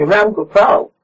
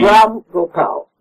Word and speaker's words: Ram Gopal: Not Rangupa Dancing Company, Rangupal. – Rangupal, right. – Rangupal. – How do you spell that? – R-A-M, one word. – Yes. – G-O-P-A-L. – Second word. – Second word, yes. – G-O-P. – Ram Gopal. – Right. – Ram Gopal, yes.